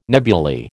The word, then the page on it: nebuly: American English